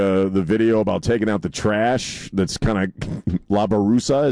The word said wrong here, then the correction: basura: Is it? Did I say that correctly? – And, basura. – What's that? – Basura. – La Basura. Okay.